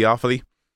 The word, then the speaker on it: Offaly: Tell me if I'm pronouncing this right. Is it Offaly? – Yeah, in Offaly, yeah.